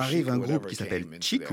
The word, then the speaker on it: Chic: Ils regardaient le nom du groupe, on prononce chic. Non, non, non, on dit chic, on est français.